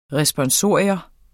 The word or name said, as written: RESPONSORIUM